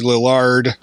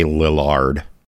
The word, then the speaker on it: Lillard: Josh Hutcherson, Matthew Lillard. – You're the only person I've ever heard say Lillard. – Did I pronounce it incorrectly? – I've always heard it Lillard.